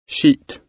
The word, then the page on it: sheet: British English